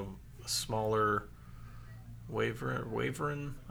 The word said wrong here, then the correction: Wyvern: I can never pronounce that right either. Um, Wyvern, I think. Wyvern, that's it.